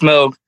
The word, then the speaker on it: Moog: It's Moog.